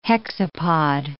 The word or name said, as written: hexapod